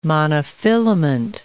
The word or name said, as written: monofilament